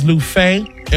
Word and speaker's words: Luffeigh: Last name is Lufay. L-U-F-F-E-I-G-H. And one F is silent.